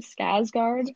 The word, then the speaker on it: Skarsgård: So with Bill, how do I say his last name? – Skarsgård. – My sister told me it's Skarsgård.